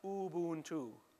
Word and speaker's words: Ubuntu: By the way, it is pronounced Ubuntu. Get it right. Don't say Ubuntu. It's not pronounced that way.